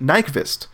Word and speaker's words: Nyqvist: Nyqvist.